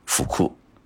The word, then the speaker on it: 服: 不能洗澡，因为日语当中啊，呃，衣服的服和幸福的福是同音，多念成福库，